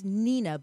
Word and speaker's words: Hnina: And it's Nina, but it's spelled H-N-I-N-A.